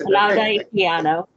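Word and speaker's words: Olaudah Equiano: I want to make sure I get his name right. Olauda Equiano? – Yes, Olauda Equiano. Yep.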